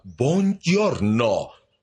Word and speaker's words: Buongiorno: Se pronuncia Buongiorno. Su boca se vuelve perezosa en la segunda sílaba.